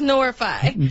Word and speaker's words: Norify: I've been pronouncing it Noirify. It's much harder to say. – Yeah, so just Norify.